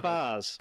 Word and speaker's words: vase: Next question on YouTube from Vase Vars, whatever you want to say, depending on your pronunciation of flowers, is... – It's Vars. We're not in America.